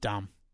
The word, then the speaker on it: dumb: We've got an old friend, Corbin, whose nickname is Arnie, who has a saying which I love, and it just goes, D-U-M silent B. – Yeah. – That was just dumb.